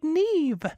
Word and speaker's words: Nevo: Did you know that I have an aunt called Nevo, which in Malagasy is pronounced like Neve?